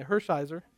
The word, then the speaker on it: Hershiser: That doesn't look like how it's spelled, but anyway, Hershiser.